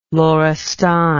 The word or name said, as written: lorestan